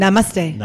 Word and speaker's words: Namaste: Namaste.